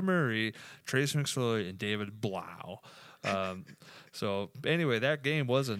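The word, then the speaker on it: Blough: How do you say that last name? – Blau. – Blau. Blau. Yep.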